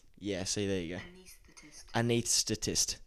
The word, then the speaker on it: anaesthetist: Anaesthetist. – Anaesthetist. Anaesthetist. It's like you say the TH before the S, but the S is before the TH.